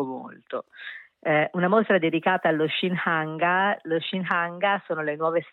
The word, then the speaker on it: Shinhanga: una mostra sull'arte degli Shinhanga.